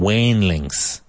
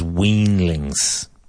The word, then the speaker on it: weanlings: Now, that comes from wainlings. The correct pronunciation of which is weanlings.